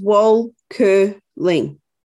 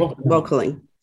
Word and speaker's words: Wolkerling: So it's pretty much the way it's spelled. So three syllables, Wolkerling. – Okay, Jody Wolkerling. – Yeah, Wolkerling.